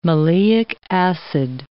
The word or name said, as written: maleic acid